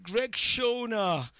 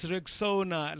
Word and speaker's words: Rexona: A friend of mine even called it Rexona. But guys, come on, it's Rexona, like Rex and the owner, Rexona.